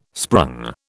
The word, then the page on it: sprung: British English